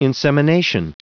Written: insemination

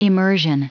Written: immersion

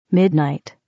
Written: midnight

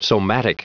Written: somatic

